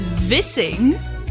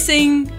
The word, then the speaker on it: Vissing: Vissing, kissing, all spelled the same way. But without fail, every time someone reads my last name, they call me Vissing.